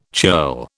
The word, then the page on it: churl: British English